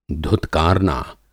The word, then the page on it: धुतकारना: Hindi